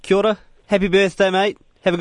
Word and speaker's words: kia ora: We say kia ora. – Kia ora. – Because that's the official that's the proper pronunciation.